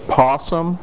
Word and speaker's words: PSSM: It's been called a position-specific scoring matrix, which sometimes gets abbreviated PSSM, and people pronounce it possum, like the animal.